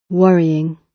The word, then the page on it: worrying: British English